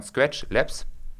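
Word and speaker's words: Skratch Labs: Und die ganze Sache kommt von Scratch Labs.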